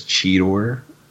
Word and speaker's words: Cheetor: Did anybody notice that he actually says Cheetor instead of like how he's been sort of mispronouncing it since this?